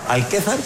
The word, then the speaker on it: Alquézar: ¿Alquézar? Sí, Alquézar. – Alquézar, lo he pronunciado bien, que es el CEO de los Gallos CIGP, que es el equipo español, el team.